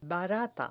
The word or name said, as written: barata